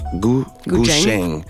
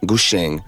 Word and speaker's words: Gu Zheng: Ça se prononce Gu Zheng. Ouais, Gu Zheng. C'est plus un CH, un TCH, mettons.